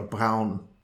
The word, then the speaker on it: Braun: was known over here by the German pronunciation of his name, Victor Braun.